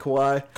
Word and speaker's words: Kawhi: Kawhi. That's how you say it, Kawhi? – Kawhi.